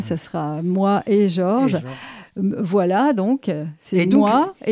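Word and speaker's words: Voix-là: Voilà. Mmh. Et Voilà s'écrit V-O-I-X, plus loin, là.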